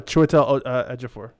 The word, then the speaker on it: Chiwetel Ejiofor: Chiwetel Ejiofor. I don't know. – Oh, Chiwetel Ejiofor.